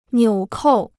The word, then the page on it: 纽扣: niǔ kòu